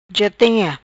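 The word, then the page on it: Ja Tem Ia: patua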